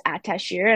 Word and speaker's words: Ataşehir: And then they're like, the S is like a sh, so it's just Ataşehir. And I was like, okay, well, that's a lot easier than what I was pronouncing. Yeah. It's a lot simpler than it looks.